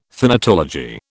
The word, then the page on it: thanatology: British English